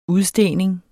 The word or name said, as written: udstening